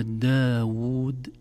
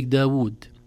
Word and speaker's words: داود: لكن الغاوون كتبت في المصاحف الأولى بواو واحدة فقط، لكن العرب تستطيع نطقها بواوين، وكذلك داود بواو واحدة فقط، لكن حتى احنا لو كتبت بواو واحدة ننطقها بواوين لعلمنا أن الكلمة تنطق بهذه الطريقة.